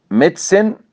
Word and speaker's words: medicine: وخلي بالكم بقى حضرتك في ثلاث كلمات بنخطئ كثير في نطقهم، منهم اللي هي medicine business history. احيانا نقول ميديسن عشان الاي، بيزنس عشان الاي، لا، او هيستوري عشان الاو، لا، هي مقطعان ضيا سيلابك. ميديسن بيزنس هيستوري، امتى اقول الاي؟